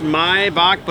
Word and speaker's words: Maibock: It's pronounced Maibock, but it does mean May.